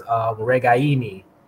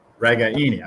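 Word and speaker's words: Ragaini: We learned something today, how to pronounce Nico's last name, which we have all been pronouncing wrong for years now. So apologize to him and his family, but it is Regaini. Emphasis on the I in the third syllable.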